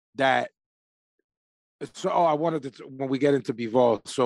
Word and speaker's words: Bivol: Bivol. – So it's the stress on B now. Before it was this, the. – I'm sticking with my Bivol.